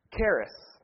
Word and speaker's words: charis: They mispronounce it, I know, but charis. C-H-A-R-I-S.